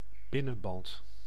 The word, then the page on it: binnenband: [ˈbɪnənˌbɑnt]